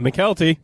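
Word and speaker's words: Mykelti: And for my last one, your guy's favorite actor, whose name is actually pronounced Michael T. Williamson. – McKelty.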